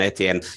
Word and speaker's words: Etienne: You know, they got rid of Robinson, and I'm not having a mispronunciation on Etienne. That's how it's said.